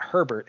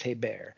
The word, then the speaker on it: Hebert: It's not Herbert, it's Hebert.